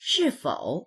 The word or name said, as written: shi4fou3